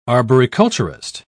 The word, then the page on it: arboriculturist: British English